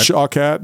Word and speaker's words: Shawkat: But anyway, to move on from your question, another person, I don't know how to say her name exactly, but Aaliyah Shawkat, right? – Shawkat.